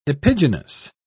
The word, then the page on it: epigenous: British English